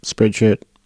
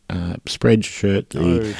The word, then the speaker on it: Spreadshirt: Spreadshirt. – Yes. – I said that right. Good. – You did. – I was about to say sweatshirt. Spreadshirt.